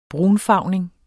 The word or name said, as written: brunfarvning